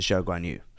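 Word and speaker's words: Zhou Guanyu: No, it's it's the so his name is spelled uh, that way, but Chinese pronunciation is Zhou Guan Yu, so it's the other way other way around.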